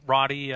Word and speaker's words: Roddy: That's correct. It's Roddy. When I was younger, the name was mispronounced as Rody or Rudy. But when Rowdy Roddy Piper became more popular, people started pronouncing my name correctly more often.